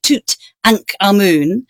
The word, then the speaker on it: Tutankhamun: But if you're saying it properly, you'd say Tutankhamun. And it's because he was renamed after Amun, who was one of the great powerful gods of ancient Egypt, rather than Aten, who was the god that his father worshipped, who is the great kind of sun disk.